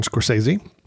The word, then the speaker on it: Scorsese: Killers of the Flower Moon by Martin Scorsese. Did I say that right? – Yes.